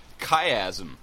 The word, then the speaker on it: Chiasm: And Emily Roan, who is the band Chiasm, was very nice enough to send me a sound file with the proper pronunciation of the band. So it is Chiasm.